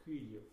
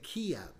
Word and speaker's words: Kyiv: Kyiv. – Kiev. – Kyiv. – Okay. We on the news we hear Kyiv, and I've been saying Kiev.